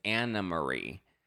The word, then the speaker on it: Anne Marie: I mean, we rehashed like Crystal versus Anne Marie, which we learned her name, even though it's spelled Anne Marie. Her name is Anna Marie, or as many of you like to call her, 8.5, which is so shady.